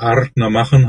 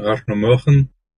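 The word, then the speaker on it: Ardnamurchan: Ja, es gibt also dieses ganz korrekte, würde Adnamurchan heißen, Adnamurchan. Also dieses Ö-Klingende, das kann man also ein bisschen als Deutscher bringen und weil für uns Deutsche ist es eigentlich nicht richtig aussprechbar.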